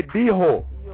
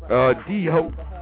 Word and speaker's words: dijo: Yeah, spell it que dijo, Q-U-E with the little tilde mark, dijo. D-I-J-O, you know, H is, the J is pronounced, you know, H.